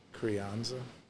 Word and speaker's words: Crianza: Crianza.